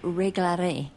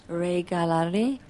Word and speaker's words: regalaré: Yo regalaré. – Say it again, please. – Regalaré. – Regalaré. – I left out a syllable the first time, didn't I? – Sí.